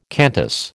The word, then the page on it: Qantas: American English